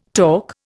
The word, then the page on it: talk: Indian